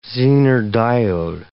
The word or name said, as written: zener diode